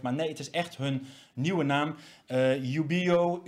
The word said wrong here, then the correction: Ubeo: Ubeo, ehm, eh, Ubeo mag je gewoon in het Nederlands uitspreken. Uh, Surf wat ehm, oorspronkelijk ook vanuit een Engelse organisatie is ontstaan.